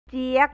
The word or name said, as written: jiag